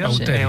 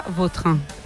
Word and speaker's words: Vautrin: Es que a mí me suena mejor Bauterín. Es como... – Daros fuerza, ¿no? – Más puede repetir más la pronunciación. – Bauterín. Sí. Elegante. – La original francesa, Vautrin. – Ahí pega un Bauterín.